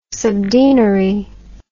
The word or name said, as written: subdeanery